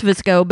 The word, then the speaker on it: VSCO: And it's pronounced Visco, but it's the one that it's VSCO in all caps as the app name.